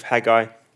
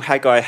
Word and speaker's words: Haggai: The second thing is I call Haggai Haggai, and apparently the correct way to say it is Haggai.